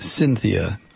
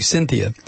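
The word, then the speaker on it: Synthia: The creation of a new life form, they're calling it Cynthia, only it's spelled S-Y-N, as in synthetic, Cynthia.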